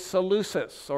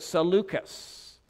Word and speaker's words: Seleucus: Ptolemy had as a prince, a commander, a man named Seleucus, or some pronounce it Seleucus.